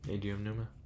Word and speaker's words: Eiji Aonuma: Aonuma, Eiji Aonuma.